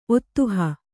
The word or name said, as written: ಒದಹು